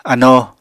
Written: anoo

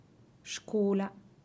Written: xcula

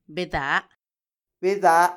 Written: bedaa

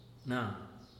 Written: na

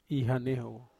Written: hanehowo

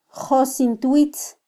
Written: josintwitz

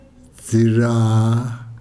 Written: dzira

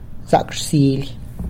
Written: Zac rsily!

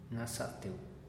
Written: naza'ateu

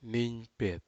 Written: nigpet

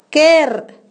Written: tamafa

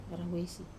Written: rawesi

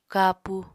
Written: kapu

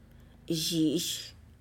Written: zhi'ish

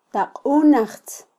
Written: taqunajtz

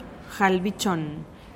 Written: jalbichonn

